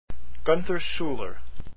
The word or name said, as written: schuller